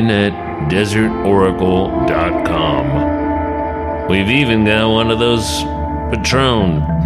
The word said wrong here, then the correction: Patreon: things. Patreon. I always pronounce it like the tequila.